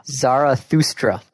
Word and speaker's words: Zarathustra: Thus. Repeat after me. Thus. – Thus. – Sprach. – Sprach. – Zarathustra. – Zarathustra.